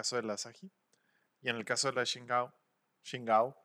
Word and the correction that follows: Tsingtao: Eh, sobre la pronunciación china de, e, en real del nombre. Entonces haré lo que nuestros amigos españoles dijeron, como ya dije, Tsingtao.